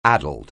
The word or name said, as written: addled